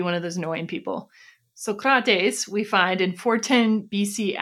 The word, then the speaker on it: Socrates: We now come to Socrates, or rather Socrates, which apparently is the way you're supposed to pronounce that.